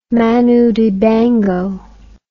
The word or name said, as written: Manu Dibango